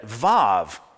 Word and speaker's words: Vav: O, the holom, even if when I read it, Vav, modern Hebrew grammar spells that letter, W-A-W. It sounds like a V, and when you pronounce it, it sounds like a V.